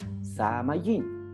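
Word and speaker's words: Samayin (שמים): Ahora, cielo en el hebreo se pronuncia Samayin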